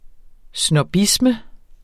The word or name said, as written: snobbisme